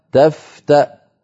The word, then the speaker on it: تفتأ: المثال الثاني في اللوحة تفتأ تذكر بهمزة مضمومة والواو التي تحتها كرسي لها، وأما الألف التي بعدها فأيضا ألف زائدة كتبت لحكمة الله أعلم بها لكننا لا ننطقها لا وصلا ولا وقفا.